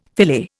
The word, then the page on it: filet: Indian